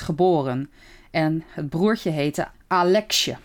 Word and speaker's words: Aleksej: Alexje. Ik hoop dat ik het goed uitspreek. Het is in ieder geval A-L-E-K-S-E-J. Maar in ieder geval... Ja, volgens mij spreek je het goed uit.